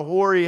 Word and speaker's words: hoary: If you read the Proverbs, it says in the Old King James language, I think it calls it the hoary head, right? H-O-A-R-Y is how you spell it.